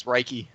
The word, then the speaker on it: Reiki: It's not Reich, it's Reiki.